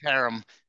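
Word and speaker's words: herem: You got the little phlegm. – Harem. Yeah. – The more spit that comes out of your mouth, the better. – Yeah, if you're spitting on your microphone, you probably said it right.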